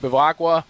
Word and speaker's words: Bevacqua: Pete Bevacqua, PGA of America, did I pronounce his last name right? – Yeah, you did.